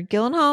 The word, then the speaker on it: Gyllenhaal: I like Jake Gyllenhaal or whatever. Or Gyllenhaal, am I saying it right?